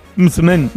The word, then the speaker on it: msemen: Es una palabra complicada, decías M-S-E-M-E-N. Mitzmen, Mitzmen, ¿vale? Y se pronuncia como lo ha hecho Hakima.